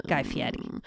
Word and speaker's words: Fieri: Is that how you actually pronounce his name, or do you make that up? – No, I think that's it. – Oh, I love him. – Guy Fieri.